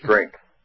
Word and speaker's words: strength: Yeah, I looked it up in Webster's Dictionary, you're supposed to pronounce it as if there's a K in it instead of a G. It's strength.